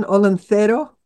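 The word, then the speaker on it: Olentzero: Well, I would give it the Spanish pronunciation, Olentero.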